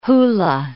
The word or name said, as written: hula